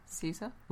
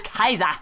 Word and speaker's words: Caesar: Say the name Caesar. – Ooh. I tingle with recognition. – Gaius Julius Caesar. – Woo! I do declare. – Or should we do the hard C? No. – Kaiser.